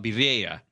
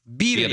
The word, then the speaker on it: birria: I have a question, though, about birria. Birria. – Birria. Yeah. – Birria. – Yeah. – Emphasis on the first syllable. Birria.